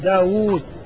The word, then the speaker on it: داود: ولكنها تنطق بواوين داود